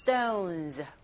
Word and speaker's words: stones: stones 발음하실 때 st 같이 보이죠. strange도 마찬가지고 st, sp, sk 이렇게 s 사운드와 만나는 p, k, t 이 소리들은 된소리 현상, 즉 경음화 현상에 의해서 stones가 아니라 stones, stones with strange strange shape.